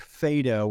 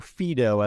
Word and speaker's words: Phaedo: It comes from Plato's work Phaedo or Phaedo, as it's variously pronounced,